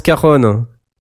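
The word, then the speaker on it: cajón: Euh, ça s'écrit cajon hein, pour ça nos cajon. C'est euh, j o n mais ça se prononce euh, caron.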